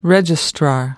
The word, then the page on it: registrar: American English